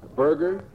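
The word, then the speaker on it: Borger: One here is from Wilma Dobkins of Berger, Texas, and she says, "The horses took me." Barger.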